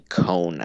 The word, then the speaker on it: Kony: Well, that's the warlord that they were in opposition against, and his name isn't pronounced Kony like it is in the movie. That's wrong. It's actually Cone, I think.